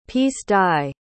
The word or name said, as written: piece-dye